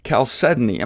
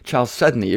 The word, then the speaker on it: chalcedony: Choose your own pronunciation. I'll call it chalcedony. It could be chalcedony, but that sounds like a city in Pennsylvania, so I'm going to call it chalcedony, the most valuable variety of jasper.